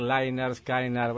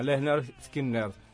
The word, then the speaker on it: Lynyrd Skynyrd: Y bueno, ahí pusieron el pronouncer porque cómo se pronunciaba, porque si escuchabas la I es Y, entonces mucho puede decir Lynyrd Skynyrd. Bueno, Lynyrd Skynyrd.